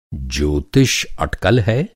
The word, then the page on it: अटकल: اٹکل